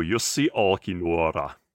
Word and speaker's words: Jussi Olkinuora: Hello, Jussi Olkinuora.